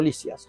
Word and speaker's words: Lisis: Y ahí tiene un diálogo entre Platón y un joven aristocrático llamado Lisias o Lisis, cualquiera de las dos pronunciaciones.